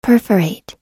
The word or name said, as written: perforate